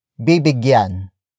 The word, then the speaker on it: bibigyan: It is the focus marker that introduces the main noun of the sentence, but in this case it's bibigyan. Bibigyan. Bibigyan. Bibigyan is spelled as B-I-B-I-G-Y-A-N.